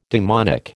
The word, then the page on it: demonic: American English